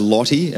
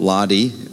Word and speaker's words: Lotty: So let me just tell you, this guy is Lottie, as in L-O-T-T-Y, not Lardy, how you guys pronounce it, but Lottie, okay, just squaring that away.